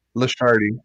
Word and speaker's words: LaCharite: And Mike Lasciardi, I think I got your name wrong at first, but Lasciardi it is. – Lasciardi.